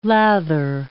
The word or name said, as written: lather